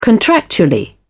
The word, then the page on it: contractually: British English